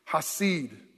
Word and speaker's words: hasid: It's a very interesting word that he uses, the Hebrew word, hasid. Hasid, H, long A-S-I-D. Hasid, he calls saints the hasid.